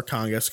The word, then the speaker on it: Kangasniemi: Because I could see how you can pronounce it Kangas. It's more Kangas because it's how the Euros pronounce it.